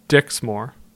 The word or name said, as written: Dixmoor